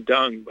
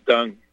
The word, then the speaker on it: Deng: That's pronounced Wendy Deng, by the way. D-E-N-G is Deng, like Deng Xiaoping, the Deng.